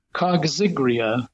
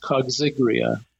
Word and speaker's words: coggygria: The main species grown is called Cotinus coxigria, which is an odd pronunciation, but especially it's spelled C-O-G-G-Y-G-R-I-A.